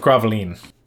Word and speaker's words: Gravelines: With the Spanish fleet scattered, the English closed in for battle, and this was the Battle of Gravelines. It's spelled Gravelines, but it's French, so.